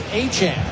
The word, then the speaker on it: Etienne: Trevor Etienne said the proper pronunciation of his last name is Achane.